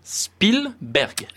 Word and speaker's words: Spielberg: Spielberg.